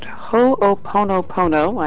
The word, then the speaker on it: Ho'oponopono: We're going to talk to Dr. Lynn about Ho'oponopono. I hope I'm finally saying that right. – Perfect. – Well, yay. – Perfect. – I practiced all day, Dr. Lynn. – You must be a Hawaiian at heart.